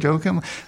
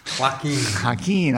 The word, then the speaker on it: Joaquin: Is it Joachim? – You know that pronunciation better than I do. – Yeah, well. – Joaquin.